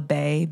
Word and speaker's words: Bey: The name my mother gave me is Angela Bey, B-E-Y.